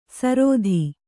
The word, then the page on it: ಸರೋಧಿ: sarōdhi